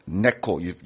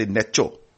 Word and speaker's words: Necho: Neko, you pronounce Neko, so I don't know how you're sometimes ch, sometimes k. You are very good. Right, Neko, right? If it came to Neko, you never say Necho.